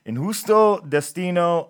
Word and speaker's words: Injusto Destino: So you got to pronounce the J's correctly. I wasn't doing that. Injusto Destino.